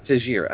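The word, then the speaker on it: Teixeira: Now, the way this would work is the winner of John Jones and Texeria. Am I saying that right? – Tajira. – I say it. God damn me. But Tajira, the winner of that fight, will go on and fight Gustafsson.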